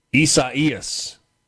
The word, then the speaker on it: Isaias: Right now, the timeline, somebody said that it's, Caleb in Starkville says that this is pronounced Isaias, the Spanish and Portuguese pronunciation for Isaiah.